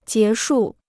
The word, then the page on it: 结束: jié shù